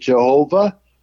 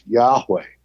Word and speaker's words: Yahweh: The older generation used to pronounce it Jehovah, but we know it more likely it was pronounced Yahweh.